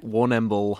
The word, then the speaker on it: Warrnambool: Am I sounding saying that right? – Warrnambool. Warrnambool.